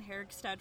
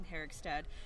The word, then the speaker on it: Herigstad: I also have a website, and it's just my first and last name, Megan Herigstad. And that's MEAGANNHERIGSTAD. And don't be surprised if you can't pronounce it, because I couldn't pronounce it when I first got married.